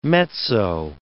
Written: mezzo

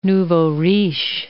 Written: nouveaux riches

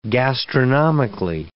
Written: gastronomically

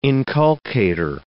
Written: inculcator

